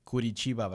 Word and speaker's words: Curitiba: And Maria, you're originally from, let me pronounce this correctly, Curitiba, Brazil.